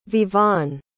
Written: vivaan

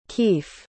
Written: Keefe

